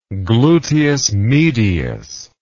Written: gluteus medius

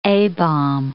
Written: A-bomb

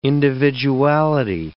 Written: individuality

